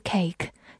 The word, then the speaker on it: cake: cake，c a k e，cake，字母 a 发它本身的音 a，末尾的 e 不发音。 cake，它是一个名词，表示蛋糕。我们之前学习 piece 这个单词的时候有讲过，a piece of cake 就是一块蛋糕，也可以引申为很容易、很简单。